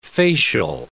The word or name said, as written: facial